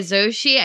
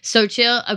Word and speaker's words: Xochitl: It's by Xochitl, I think is how you say her name. Xochitl Gonzalez. Xochitl.